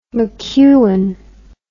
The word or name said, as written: mcewan